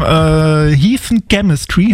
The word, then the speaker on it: Heathen Chemistry: Wir machen heute ein Lied aus dem Jahr 2002 und da gab es das Album Heathen Chemistry.